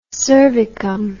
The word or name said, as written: cervicum